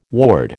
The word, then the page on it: ward: American English